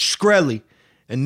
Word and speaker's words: Shkreli: For fuck's sake, it's Shkreli, and no amount of intentional extreme mispronunciations will convince me you're not terrified of saying Shkreli. Yeah, Shkreli.